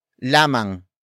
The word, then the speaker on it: lamang: The next word is lamang. Lamang. Lamang. Lamang is spelled as L-A-M-A-N-G.